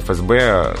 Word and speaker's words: ФСБ: Достаточно новые аббревиатуры, и в них э, произносится буква F так, как она произносится в литературном языке. F. – Я всегда слышал ФСО как раз. – Но вот надо говорить ФСО. ФСО, ФСБ, словари нам делят говорить именно.